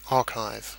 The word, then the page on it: archive: British English